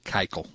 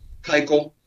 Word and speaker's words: Keuchel: We heard that they, you know, that they weren't interested in Cutchell, isn't that how you pronounce his name? – Keuchel. – Keuchel.